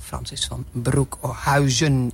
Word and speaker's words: Francis van Broekhuizen: En verder wordt het gepresenteerd door Francis van Broekhuizen, is het, hè?